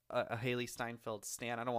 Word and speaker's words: Steinfeld: I get them confused because Steinfeld, or Stein, I think it's Steinfeld. Is that how you pronounce it? – Yeah. – It sounds like Seinfeld,